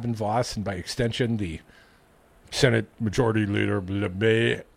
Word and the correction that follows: LeMahieu: how do you say it? – LeMahieu.